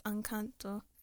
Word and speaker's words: Encanto: I think Enchanto. I don't know. – Enchanto. I don't know how to pronounce Spanish. – Isn't it Spanish? Isn't it Encanto? – Yeah, I think it's Encanto.